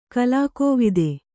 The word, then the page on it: ಕಲಾಕೋವಿದೆ: kalākōvide